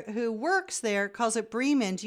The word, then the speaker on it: Bremen: I just wanted to make a gentle comment about the correct pronunciation of the town Bremen, B-R-E-M-E-N. The fully correct German pronunciation would be Bremen, not Bremen.